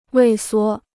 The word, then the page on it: 畏缩: wèi suō